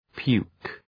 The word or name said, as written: puke